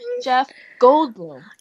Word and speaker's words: Jeff Goldblum: Say his name again because you just butchered it. – I'm so excited that I butchered his name. Jeff Goldblum.